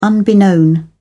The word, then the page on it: unbeknownst: British English